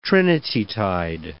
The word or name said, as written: Trinitytide